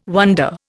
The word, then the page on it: wonder: Indian